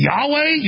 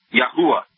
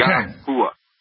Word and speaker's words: Yahuwah: Is it Yahweh, Yeshua, or does everybody argues how to say it? Or how are you saying say it? – Yahuwah. – Okay. – Yahuwah.